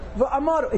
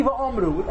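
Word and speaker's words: ויאמרו: They pronounce it Zek Keli Uni V'Amuru. V'Amaru. It's so bad. And I saw in the new Siddurim, they actually put a comment under the men. It's supposed to be V'Amru.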